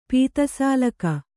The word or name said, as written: ಪಿತಸಾಲಕ